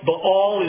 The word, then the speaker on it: Baal: Baal is the proper pronunciation there. I learned while I was in Israel, Baal.